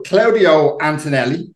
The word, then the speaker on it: Claudio Antonelli: And we'd like to you all to give a very big Port Pals welcome to Claudio Antonelli.